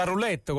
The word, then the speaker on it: roulette: E quindi si gioca la roulette con l'accento sulla O, giusto? – Ma più o meno sì.